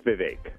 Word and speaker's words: Vivek: You're close. It's Vivek. Like it rhymes Vivek. With lake.